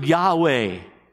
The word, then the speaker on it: Yahweh: Theologians call it the tetragrammatron, or YHWH, no vowels. We pronounce it Yahweh.